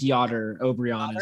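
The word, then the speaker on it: Jáder Obrian: I've heard it Yotter. I think Yotter Albrion is the, yeah, I think that's going to be the best way.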